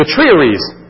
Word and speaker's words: triarii: And the third line of people are the triaries. The triaries, T-R-I-A-R-I-I. That's the best pronunciation I can do for you. Triaries. Okay, two I's, I think it makes an E.